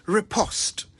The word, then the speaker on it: riposte: R-I-P-O-S-T-E. Riposte. Now, it's worth mentioning that the English say riposte, but the Americans say riposte. And as I always remind you, the national language of Ghana is English, not American. So we're going with riposte.